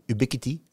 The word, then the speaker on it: Ubiquiti: Kijk, als jij op elke verdieping een keurige Ubiquiti hebt hangen. Ubiquiti, ja, zo spreek je het bijna uit.